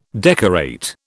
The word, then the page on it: decorate: British English